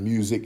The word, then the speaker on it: Musiq: And Music is spelled M-U-S-I-Q.